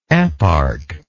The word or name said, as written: eparch